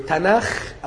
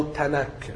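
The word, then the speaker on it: تناخ (תנ״ך): المهم هم في الاخر اليهود بينطقوه تناخ او تناك بحسب، يعني في ناس ما بيعرفوش ينطقوا، الاجانب ما بيعرفوش ينطقوا الخاء فبينطقوا كاف. هم لا، اليهود بيسموا العهد القديم تناخ.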